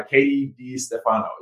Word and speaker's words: Catie Di Stefano: I'm your host, and today we are joined by Catie Di Stefano.